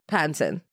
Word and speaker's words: Pattinson: Pattinson.